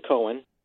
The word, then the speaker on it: Coen: This is called Holmes and Watson, and it is a comedy mystery being directed by and written by Ethan Coen.